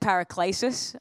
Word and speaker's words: paraklesis: I said paraklesis, I think, this morning, and someone came up to me and was like, "It's paraklesis." And I was like, "Oh, sorry." So I get another shot to pronounce it right.